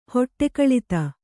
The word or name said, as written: ಹೊಟ್ಟೆಕಳಿತ